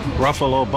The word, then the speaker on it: Ruffalo: Welcome. Ruffalo, Buffalo.